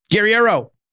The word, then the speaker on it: Guerriero: Guerriero.